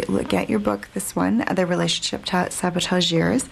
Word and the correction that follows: Saboteurs: And am I pronouncing that right? I've been wanting to ask you that all show. So, Sabotageurs. Am I saying that correctly or not? – Saboteurs. – Yeah. Thank you. – It's a hard word because it's kind of a French word. Mm-hmm. It comes from the word sabotage.